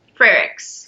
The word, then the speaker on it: Frerichs: And Courtney, I'm going to say your last name wrong, Frerichs? – Frerichs.